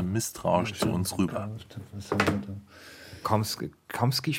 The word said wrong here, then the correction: Chomsky: spricht man ihn aus? Ähm, Chomsky.